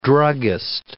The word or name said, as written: druggist